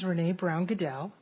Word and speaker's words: Renee Brown Goodell: My name is Renee Brown Goodell, R-E-N-E-E, Brown, just like a color. Goodell, G-O-O-D-E-L-L, as we like to say in my family, like Roger, but nobody hates us.